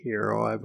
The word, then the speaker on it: gyro: Some people say gyro, some people say hero. – Heroes. – Heroes, yeah. – Heroes. – This is the correct way to pronounce that.